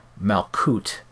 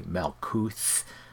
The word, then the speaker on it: Malkuth: Note that all of those words that contain the TH, I'm not pronouncing it as th, but as t. And this is just a matter of preference. Some people say Malkuth and Kether and Tifereth, etc.